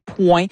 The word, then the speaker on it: Pwen: Quand elle dit « Nan Pwen la vie anko », entre autres, le « Pwen », je devrais le prononcer « point ».